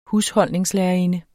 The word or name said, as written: husholdningslærerinde